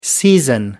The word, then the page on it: season: American English